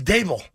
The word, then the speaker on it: Daboll: Yeah, so I guess that came out of the press conference today that we've all been mispronouncing his name. It is not Brian Daboll. It's Brian Dable.